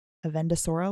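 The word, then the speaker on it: Avendesora: Avendusora leaf? That is how you say it. – I'm not going to be able to pronounce this. Proceeds to perfectly pronounce this. – The key is an Avendusora leaf, which we have heard before mentioned a couple times.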